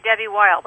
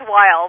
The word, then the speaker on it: Debbie Weil: Welcome to the show, Debbie Wheel, the corporate blogging guru. – Donna, thank you. It's actually Debbie Weil, but that's neither here nor there. – Am I saying it wrong? – It is Weil, but it doesn't matter.